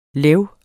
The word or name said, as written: leu